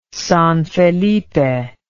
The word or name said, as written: San Felipe